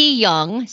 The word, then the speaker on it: Jung: But probably the best part of the weekend was that Mickey Young, so it's spelled J-U-N-G, he's from Germany, Mikkel, but they call him Mickey.